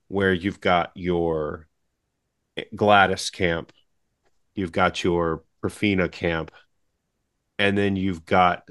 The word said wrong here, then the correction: Perfidia: that's right, am I saying that wrong? – Perfidia. – Perfidia, sorry. Perfidia.